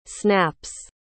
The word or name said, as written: snaps